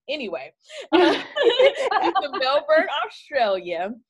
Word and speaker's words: Melbourne: Melbourne? – That's right. Melbourne.